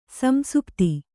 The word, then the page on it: ಸಂಸುಪ್ತಿ: kannada